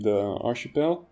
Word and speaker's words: Archipel: So we create a channel named Archipel. Well, we pronounce it Archipel because we comes from French, and we're keeping the French pronunciation.